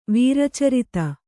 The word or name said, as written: ವೀರಚರಿತ